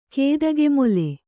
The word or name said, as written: ಕೇದಗೆಮೊಲೆ